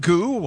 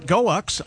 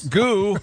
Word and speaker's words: Goux: We're spelling goo, G-O-U-X. – Is that how you pronounce that? – I would think goo or goax. It's not goax, I can tell you that.